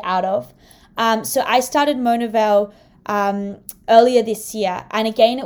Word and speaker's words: Monaville: but Monavil? – Yes. – Oh, sorry, how do you pronounce it? – Monavil. – Monavil.